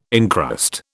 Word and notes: incrust: British English

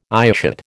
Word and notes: eyeshot: American English

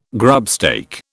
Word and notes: grubstake: British English

